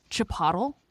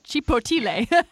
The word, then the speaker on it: Chipotle: I think it's pronounced Chipotle. I don't know what this Chipotle is, first of all. – Oh, Chipotle? – Chipotle.